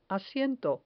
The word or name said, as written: asiento